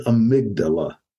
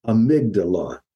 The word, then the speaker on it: amygdala: And that structure is called the amygdala, amygdala. And you spell that A-Y-A-M-Y-G-D-A-L-A, A-M-Y-G-D-A-L-A, the amygdala.